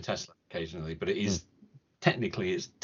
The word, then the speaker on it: Tesla: And if I might just pick up on one small detail from this week's Smith & Sniff, it is pronounced Tesla. – Oh, yes. – With the Z, not Tesla with the S. Just clearing that up, because that is how it's pronounced in the original Serbian. Tesla, not Tesla, definitely, because it's named after the man and he was Serbian and that's how it would be pronounced in Serbia.